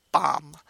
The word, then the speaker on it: Beau M: The other members of Beau Maeve's band, which is called Beau M, and I think we're supposed to read that phonetically as Bomb.